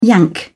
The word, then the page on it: yank: British English